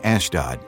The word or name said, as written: Ashdod